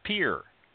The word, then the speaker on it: Pierre: And we talked to some wineries in North Dakota, and they said it's not Pierre, it's Pierre.